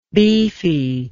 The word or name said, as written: beefiness